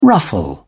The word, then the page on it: ruffle: British English